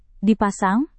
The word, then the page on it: dipasang: indonesian